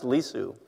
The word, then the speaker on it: Lisu: but one of those languages is pronounced Lisu,